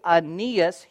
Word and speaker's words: Aeneas: So look at chapter 9, and we're going to look at verse 32, and we're going to see Aeneas healed. I believe that's how you say it. Aeneas is healed. This is kind of a weird name, A-E-N-E-A-S, but I believe it's Aeneas.